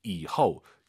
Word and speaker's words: 以后: 이후 이후 이 발음을 꼭 기억하셔야 됩니다.